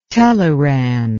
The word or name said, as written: teleran